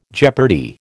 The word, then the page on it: jeopardy: American English